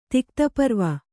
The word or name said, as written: ತಿಕ್ತಪರ್ವ